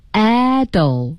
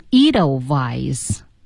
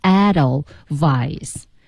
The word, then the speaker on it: Edelweiss: 读音呢是 Edelweiss 啊， Edelweiss，德语当中的 W 是 V，是我们的唇齿音 V 音、嗯、有这个词呢，有人在英国读成 Edelweiss，但是在德语中或者是唱歌的时候，我们还是要读 Edelweiss。这次好像在字典里查不到呢。 – 是。 – 所以咱得教，叫 Edelweiss。